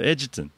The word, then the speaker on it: Egerton: E-G-E-R-T-O-N. – Okay, Egerton, Egerton, Ayrton. – Yeah, I'll go with Ayrton. – Edgerton. – Edgerton. – Oh, it just sounds common. – No, Edgerton.